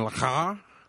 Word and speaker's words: le Carré: Other books that I got, Jean Le Carré, The Constant Gardener. – That's exactly how you say it.